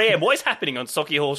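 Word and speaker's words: Sauchiehall: Sochi Hall Street. Jeez, oh, that is not how you say that. – Go on, how do you say it? – Sochi Hall.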